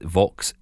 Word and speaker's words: Vaux: It's called a Vox speaker. Now, I will say that Vox, if you're looking for it, is spelled V-A-U-X.